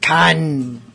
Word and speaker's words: Cian: We had Cian, or I'm sorry, I always forget how to pronounce that. That's just Can, like the Wrath of Can.